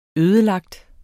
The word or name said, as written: ødelagt